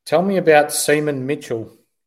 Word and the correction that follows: Seamus: Yeah, so it's actually pronounced Seamus. Seamus Mitchell. – Where do I get Seaman from? I don't know. – Yeah, it's an Irish name, Seamus Mitchell.